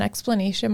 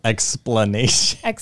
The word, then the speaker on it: exclamation: I didn't even put an exclamation mark. – Nope. Explanation.